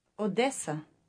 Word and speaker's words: Одеса: Одеса. – Now, listen again. I'm going to Odessa. – Я їду в Одесу. – Did you notice the change in Odessa? – Одеса.